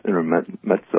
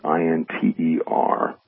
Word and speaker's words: Intermezzo: It's pronounced Intermezzo, although it's spelled Intermezzo, but it's Intermezzo.